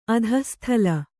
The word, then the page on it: ಅಧಃಸ್ಥಳ: adhahsthaḷa